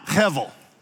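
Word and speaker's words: hevel (הבל): Say it with me now, hevel. If you're not hearing that in the back of your throat, you're not saying it right.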